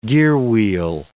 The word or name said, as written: gearwheel